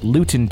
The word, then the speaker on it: Luton: I'm from the UK, and this story happened in Luton Town. Ah, I said it without even looking at the enunciation guide here. – Good. – I said it correctly.